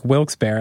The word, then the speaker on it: Wilkes-Barre: I wonder, and since you're from a place like Wilkes-Barre, I'm so glad I can say it like that. – Yeah, man. – Like, I didn't realize I've been using like the almost the bougie pronunciation of Wilkes-Barre the whole time.